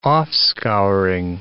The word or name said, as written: offscouring